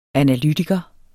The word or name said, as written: analytiker